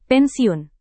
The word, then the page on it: pensiun: indonesian